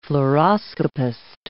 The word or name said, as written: fluoroscopist